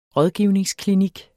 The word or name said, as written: rådgivningsklinik